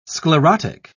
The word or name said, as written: sclerotic